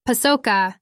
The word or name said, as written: pacoca